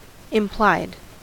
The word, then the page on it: implied: American English